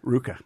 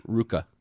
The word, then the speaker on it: RVCA: Ruka. – Ruka.